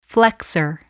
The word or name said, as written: flexor